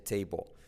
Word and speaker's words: table: The apple is on the semiclave TH table. Letra clave L que casi no se pronuncia.